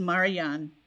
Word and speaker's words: Marjan: Before that was, I think it's pronounced Marjan. – Yeah, M-A-R-J-A-N.